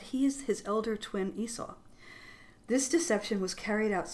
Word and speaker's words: Esau: Specifically the story of Jacob and Esau. Am I saying that correctly? – Yeah, Esau.